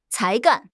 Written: 才干

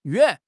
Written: yuan3

